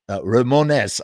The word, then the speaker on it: Ramones: In any case, as we were reading, Ramones, I love that. That's how they pronounced it down there, according to Iggy Pop.